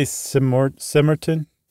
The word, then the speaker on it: Cemortan: I'd say Simortan. – Simortan or something like that. C-E-M-O-R-T-A-N.